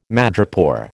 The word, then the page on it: madrepore: American English